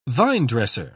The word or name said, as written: vinedresser